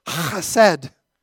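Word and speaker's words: hased (חֶסֶד): And if I was of Middle Eastern origin, I'd probably say hased, like it should be properly pronounced.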